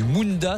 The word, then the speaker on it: Mundat: Et dans un instant, on s'enfonce dans la forêt du Munda, c'est comme ça qu'on prononce Mundat, et on aborde les terres australes et Antarctique.